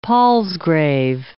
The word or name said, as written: palsgrave